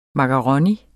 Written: MAKARONIER

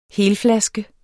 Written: helflaske

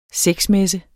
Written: sexmesse

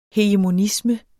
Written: hegemonisme

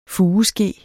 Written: fugeske

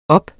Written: oppe

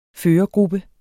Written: førergruppe

